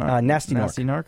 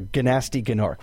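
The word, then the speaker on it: Gnasty Gnorc: Nasty Narc. Yeah. – Or Ganasty Ganork, which is how it's spelled, but it's pronounced Nasty Narc.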